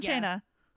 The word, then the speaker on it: Cena: I like John Cena. – That'll be fun to talk about, I think. – Also, I just want to be clear. We both know that his name is pronounced Cena.